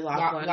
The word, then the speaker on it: Lachlan: But I think it's Lachlan Watson, L-A-C-H-L-A-N. – Probably Lachlan. – Lachlan, yeah. Watson.